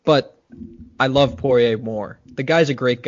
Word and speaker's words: Poirier: Lead into UFC 257, Conor McGregor versus Dustin Poirier? Did I pronounce it? – Poirier.